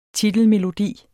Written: titelmelodi

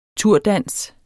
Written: turdans

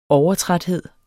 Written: overtræthed

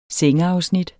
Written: sengeafsnit

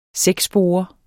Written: sækspore

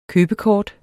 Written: købekort

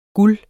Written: guld

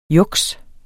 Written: juks

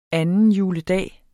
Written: juledag

